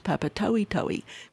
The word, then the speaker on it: Papatoetoe: This is, I'm just digressing, years ago, and it's spelt Papa, T-O-E-T-O-E. So I call it Papatoto. And they just laughed at me because it's Papatoetoe, because they pronounce each vowel. – Oh, well, that's a good tip. – You know, so a lot of the vowels we might join together, they pronounce them separately or individually.